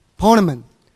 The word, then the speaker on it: Ptolemy: How do you pronounce it? Ptolemy. No P. Ptolemy.